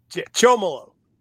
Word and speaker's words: Chomilo: Chomilo.